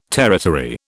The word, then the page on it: territory: British English